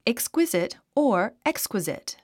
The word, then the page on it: exquisite: ekskwízit; エクスクイジト